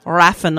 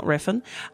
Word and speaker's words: Refn: And how you say his name? Raffin, not Refn.